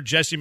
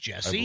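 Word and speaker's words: Jussie: It's pronounced Jesse? – I believe so. – But spelled J-U? – Yeah.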